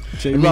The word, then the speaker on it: J-Robb: Say it like you got two B's. J-Rob. I mean, y'all say my name so soft like J-Rob. – Catches MySpace too, J-Rob Music. – MySpace/J-Rob Music. J-R-O-B-B Music.